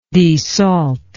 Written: desalter

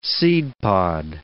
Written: seedpod

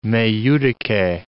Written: me judice

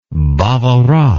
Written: bavarois

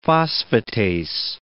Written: phosphatase

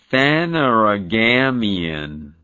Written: phanerogamia

